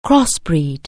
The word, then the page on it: crossbreed: anglais